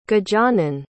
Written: gajanan

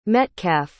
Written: Metcalf

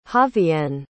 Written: javianne

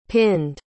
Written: pinned

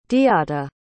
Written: diata

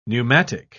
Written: pneumatic

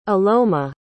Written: aloma

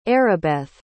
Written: Arabeth

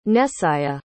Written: Nesiah

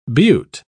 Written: butte

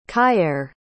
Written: kyaire

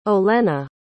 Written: Olenna

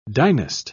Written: dynast

